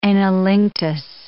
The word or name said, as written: anilinctus